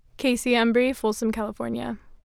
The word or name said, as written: Kaci Embree